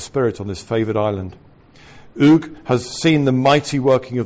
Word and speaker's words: Uig: Since the 1820s, when Lewis was totally transformed in that mighty movement, this is a prior revival, originating in, I think it's pronounced Uig, U-I-G, Uig, under Alexander MacLeod, all over Lewis, these principles of prayer and of waiting upon God for his blessings are well established.